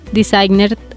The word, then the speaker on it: Desygner: Designer se escribe D-E-S-Y-G-N-E-R.